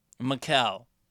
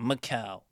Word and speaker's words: Mikal: Mikal. That's how you say his name. Mikal.